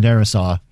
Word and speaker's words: Darrisaw: Vikings Vikings offensive tackle Christian Darrisaw. Did I, did I say that right? – You did. Yeah.